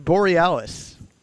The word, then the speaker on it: Aurora Borealis: Aurora Borealis. – Look at you. – Is that right? – Have some confidence in yourself, bro. – It's the Borealis.